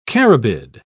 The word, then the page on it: carabid: British English